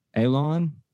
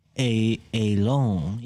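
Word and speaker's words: Ayllón: All right, so we got 1526, Spanish explorer Lucas Vasquez de Ayllon. Do you guys know how to pronounce his name? Ayllon? Ayllon? – Ayllon. Yeah, that's Ayllon.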